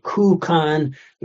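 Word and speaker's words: kukan (空間): This character also can mean spacious and it appears in certain compounds with another character like kukan, which simply means physical space. And there, yeah, it's pronounced ku.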